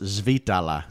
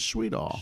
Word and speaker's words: Schwiethale: Actually, in German, it's pronounced Schwiethale. – Okay. – And I always reply, what? God bless you. – Right. – When Schwiethale. I hear that. – But the American pronunciation is Schwiethale.